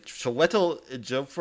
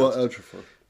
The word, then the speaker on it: Chiwetel Ejiofor: Chiwetel Ejiofor, or I can't remember. Chiwetel Ejiofor.